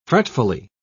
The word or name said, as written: fretfully